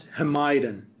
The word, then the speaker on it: Hamadan: Is it Hamadan? Hamadan? How do I say your name so I get the accent on the correct syllable? Okay. – Good morning, Your Honor. It's pronounced Hamadan.